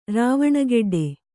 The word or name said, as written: ರಾವಣಗೆಡ್ಡೆ